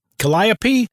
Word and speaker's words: Calliope: C-A-L-L-I-O-P-E. Calliope? – Calliope.